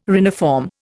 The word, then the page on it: reniform: Indian